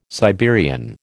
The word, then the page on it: Siberian: American English